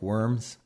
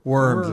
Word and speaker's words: Worms: spelled like Worms, but it's pronounced Worms.